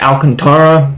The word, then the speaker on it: Alcantara: I'm going to go with Sandy Alcantara.